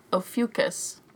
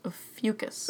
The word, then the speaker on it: Ophiuchus: It's pronounced Ophiuchus. Ophiuchus.